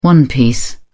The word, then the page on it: one piece: British English